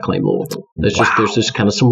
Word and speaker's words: Louisville: Louisville. – That's how you pronounce it. – That's right.